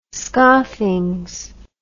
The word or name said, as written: scoffings